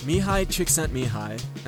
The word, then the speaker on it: Csikszentmihalyi: Mihai Csikszentmihalyi,